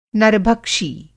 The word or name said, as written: नरभक्षी